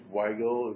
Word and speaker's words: Weigel: Well, we don't have to spend much time on that, but this stupidity you had a great example with Dave Weigel, is that how you pronounce his name? – Yes.